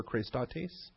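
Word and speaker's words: chrestotes: 5544 is the Strong's Concordance number for Christotes. Christotes. Both the E's are the long E's, the A's.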